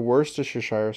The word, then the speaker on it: Worcestershire: It is usually served with onions, capers, pepper, Worcestershire sauce, and other seasonings.